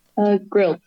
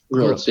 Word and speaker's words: Grilc: Grilc. – Grilc, yeah.